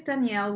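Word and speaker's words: Daniele: Sometimes, apparently it sounds like Danielle or Daniel, but it is Danielle with one L.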